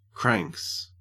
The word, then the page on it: cranks: Australian English